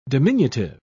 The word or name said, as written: diminutive